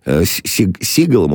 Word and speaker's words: Сигал: Ты все фильмы с Стивеном Сигалом у нас говорили. А точнее, это правильно говорить, Сигал, конечно, да, Чайка.